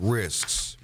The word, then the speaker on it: risks: We're looking at risks. Risks. And I'm saying that right with the S in there, risks in retirement, and different ones to avoid.